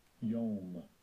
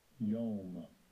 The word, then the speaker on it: yom: Now, the word day is masculine, yom, is pronounced, I think it's pronounced yom. – Strong's H3117, yom, yom.